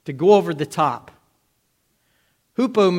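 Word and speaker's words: hupo (ὑπό): hupo. It's U-P-O, but it's a rough breathing mark, so we pronounce it H. H-U-P-O, hupo meno.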